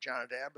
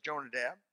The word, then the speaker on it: Jonadab: I call him Jonadab. Some pronounce it Jonadab, but it's Jonadab.